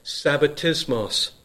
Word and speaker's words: sabbatismos: Those two words in our English Bible, Sabbath rest, they come from a single word in the Greek language, which is pronounced sabbatismos.